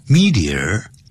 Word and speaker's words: meteor: M-E-T-E-O-R, 有两个发音 ,meteor, 或是 meteor. 两个发音都可以